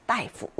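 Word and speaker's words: 大夫: 따이푸 의사. 자, 의사의 다른 표현도 있었습니다. 네, 이성이라는 표현도 있었는데 이 따이프도 많이 쓰이니까 그때 큰 대자는 따가 아니라 따이라고 읽힌다.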